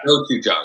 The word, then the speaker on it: gochujang: And I always keep gochujang, I think is what it's called. I know I just... – Gochujang. – Yeah. – Gochujang.